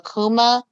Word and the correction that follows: Kama: From I'm probably saying his name wrong. I'm pretty sure I'm saying it wrong. And Fantastic Beasts. – Yusuf Kama? – Yeah, there Kama. Ooh, I was like, what's that?